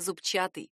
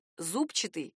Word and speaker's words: зубчатый: В прилагательном «зубчатый» так и хочется поставить ударение на «у», потому что в слове явно есть какой-то зуб. Но поддаваться этому желанию не стоит. Нормативное ударение именно «зубчатый». Большой орфоэпический словарь русского языка отмечает, вариант «зубчатый» использовать не рекомендуется.